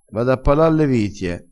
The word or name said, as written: pala